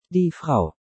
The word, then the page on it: Die Frau: German